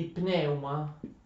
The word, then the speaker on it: pneuma: Quindi pneuma, pneuma, sempre ricordate l'accento va sulla seconda vocale, sul secondo elemento del dittongo, ma si legge sulla prima.